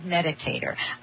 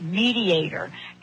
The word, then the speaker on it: meditator: I am not a good meditator. I mean, for years, I mispronounced that word. I used to call it mediator.